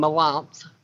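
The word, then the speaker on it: Melanthe: He's married to a character named Melanth. Melanth, I'm saying that right. It's M-E-L-A-N-T-H-E.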